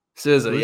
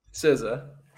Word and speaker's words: SZA: SZA, yeah. SZA.